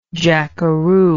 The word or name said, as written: jackaroo